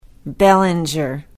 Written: bellinger

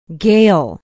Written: Gale

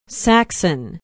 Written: Saxon

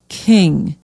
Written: king

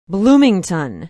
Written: bloomington

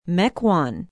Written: Mequon

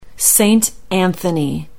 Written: saint anthony

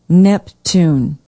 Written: Neptune

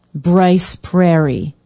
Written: brice prairie

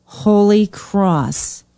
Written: holy cross

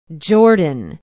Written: jordan

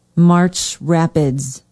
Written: march rapids

